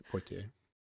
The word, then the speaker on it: Poitiers: And I think it's at this point that Julia gives us some information on where we are, which is Poitiers. I can't pronounce French. – I think that's right. Poitiers.